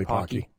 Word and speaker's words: Pocky: Yeah, it's probably Pocky. Rhymes with hockey.